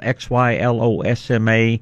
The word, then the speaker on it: xylosma: It's called xylosma. It's spelled X Y L O S M A, kind of like xylophone, but xylosma.